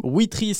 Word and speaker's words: Wetri: Merci beaucoup pour cette découverte de l'application Witry.